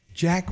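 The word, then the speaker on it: Jai: I've seen him in a few things, but her other son, Rob Will, plays Ja Courtney, and his name is pronounced Ja. I'm pretty sure it's J-A-I, and I think he pronounced it Ja, because I've seen him.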